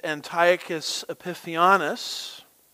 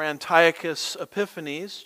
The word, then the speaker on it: Antiochus Epiphanes: It was later the empire, as Alexander the Great's empire was divided, of a man named Antiochus Epiphanes, or Antiochus Epiphanes, just depends on which syllable gets the emphasis, as we like to say.